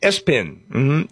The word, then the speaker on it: ESPN: Number three, the name was ESPN, pronounced Espin.